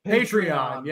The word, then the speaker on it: Patreon: Patreon.